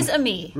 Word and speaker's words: Fils-Aimé: Fils-Aimé.